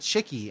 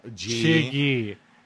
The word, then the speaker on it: Chiggy: How did you pronounce it? – Chiggy. – Okay, Chiggy is... I thought you said Chicky, and I was like, there's G's in there. – Chiggy. Chiggy, like chigger.